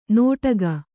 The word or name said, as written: ನೋಟಗ